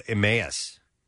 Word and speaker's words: Emmaus: Here's one I never got right is Emmaus. – You said it right.